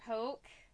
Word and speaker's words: hoke: They're hoke. – Hoke. – Hoke. – Which is like hoax without the S. – We're assuming? – Well, that's how it's pronounced.